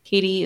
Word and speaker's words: Caitie: Katie is spelled C-A-I-T-I-E for whatever reason.